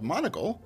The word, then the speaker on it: Monokle: And so I stumbled onto this thing called Monokle.